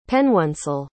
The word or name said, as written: pennoncel